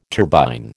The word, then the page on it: turbine: American English